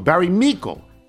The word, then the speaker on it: Meikle: Like from Barry Mikey, is that how it's B-R-M-E-I-K-L-E, Michael? Barry Michael? Rhymes with treacle. Barry Meikle in Guelph, Ontario.